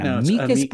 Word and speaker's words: amicus: If you took Latin, it would be pronounced amicus. You always emphasize the second to last syllable.